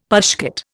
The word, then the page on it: pushcart: Indian